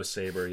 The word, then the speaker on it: Sabra: I have Sabra. – Sabra. – Yeah, the hummus. – It's hummus. – It's pronounced saber. – Oh, saber. Yeah.